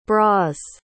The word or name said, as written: bras